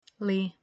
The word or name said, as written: Li